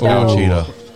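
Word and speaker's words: Ouachita: No, that's definitely pronounced Wachita.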